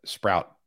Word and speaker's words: Sproat: Player of the week for me is Brandon Sprout. And before we yank this for the clip, Sprout, S-P-R-O-A-T, Sprout.